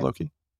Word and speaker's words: Loci: Is it Shores of Loki, right? L-O-C-I? – Yeah, I'd say Shores of Loki.